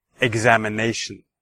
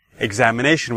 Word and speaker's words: examination: Okay, so listen, please. Examination. Examination. All right, so which syllable did you hear the stress? All right, well, hopefully you heard it in the examination, the fourth syllable, the nay.